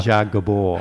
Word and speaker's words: Zsa Zsa Gabor: Zsa Zsa Gabor.